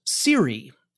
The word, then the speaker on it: Siri: The result would be a new company called Siri, S-I-R-I, which is kind of the way you would say SRI if you were trying to pronounce it as if it were an acronym as opposed to an initialism.